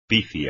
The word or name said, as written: pifia